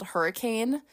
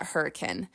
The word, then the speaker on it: Hurricane: And like there's a town down there also called Hurricane, but Utahns call it Hurricane. And I know that might sound ridiculous. Like literally say it right now, Hurricane. No, it sounds so ridiculous. But if you don't want to be clocked as a non-Utahn, go out there and start saying Hurricane in Zion.